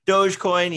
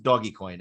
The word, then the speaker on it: Dogecoin: You didn't pronounce it doggy coin, Ed. That's okay. So you're not as out of touch as you think. You know, when some people see it and they call it doggy coin.